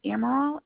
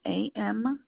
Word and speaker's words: Amaral: Yep, Amaral, Amaral.